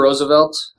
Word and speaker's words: Roosevelt: Theodore Roosevelt, that's how you say his name, right? – Yep.